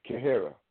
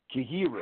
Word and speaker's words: Kheyra: Let me spell it. K-H-E-Y-R-A, Kahara, Kahira.